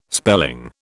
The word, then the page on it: spelling: British English